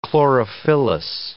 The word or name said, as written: chlorophyllous